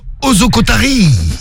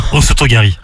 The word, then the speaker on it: osotogari: Comme Ozokotari. – Osotogari.